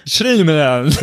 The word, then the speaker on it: Shyamalan: Shyamalan, der Mann, dessen Namen nicht korrekt ausgesprochen wird. Ja, ich packe da immer so 3-4 Silben zu viel rein. – Shyamalan.